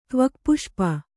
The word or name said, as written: ತ್ವಕ್ಪುಷ್ಪ